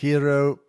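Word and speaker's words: Kyrö: So the distillery is called Kairos. Is that how you pronounce it? – Kiro, K-Y-R-O with a couple of umlauts on the top.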